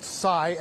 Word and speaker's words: Sai: He is identified as, we believe his name is pronounced Sai, S-A-I is the first name, Sai Varshith Kandula.